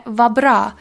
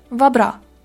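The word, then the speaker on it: vad bra: Или последнее вабра. Пишется это как вадбра, то есть с д на конце, но произносится просто вабра.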